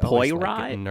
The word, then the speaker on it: Poirot: Am I saying that right? – I think it's Poirot. I'm pretty sure it's Poirot.